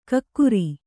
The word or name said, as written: ಕಿಕ್ಕಿರಿ